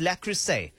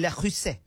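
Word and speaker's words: Le Creuset: Dua Lipa and Callum Turner gave their guests a 12-piece lacrosse. You understand, I'm not even saying it right. They gave La Crusade.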